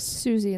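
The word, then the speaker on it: Siouxsie: Sex Pistols, The Clash, which we talk about, Suicide and the Banshees, Buzzcocks. – Susie and the Banshees. – Susie, thank you.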